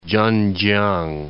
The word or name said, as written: Chen-chiang